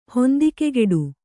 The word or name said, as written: ಹೊಂದಿಕೆಗೆಡು